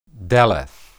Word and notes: deleth: Hebrew

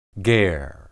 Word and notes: ger: Hebrew

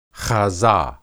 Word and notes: hazah: Hebrew

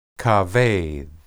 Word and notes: kabed: hebrew

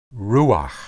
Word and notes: ruah: Hebrew

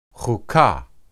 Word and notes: huqah: hebrew

